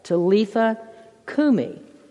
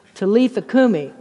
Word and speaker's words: Talitha kumi: If you wanted to know how to say, damsel, arise, or girl, get up, this is how you say it. Talitha kumi. It's what I say to my wife on Sunday mornings. Talitha kumi.